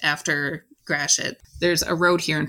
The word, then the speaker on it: Gratiot: There's a lot of French names here in Michigan, and we don't always pronounce them the way they should be. Gratiot actually came from Captain Charles Gratiot, who was an army engineer who built the actual military fort just south of the flight station. It was built in 1814. Yeah, from your high school French, it probably should be something more like Gratiot. – Right, that's better than what I said, yeah.